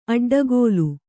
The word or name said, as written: ಅಡ್ಡಗೆಲ್ಲು